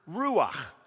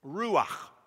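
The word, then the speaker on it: Ruach (רוח): And so as we look at the Old Testament word, it's a word, ruach. Ruach. I spit every time I do it. Ruach. It's almost like you got to say it like you got a hairball in the back of your throat, right?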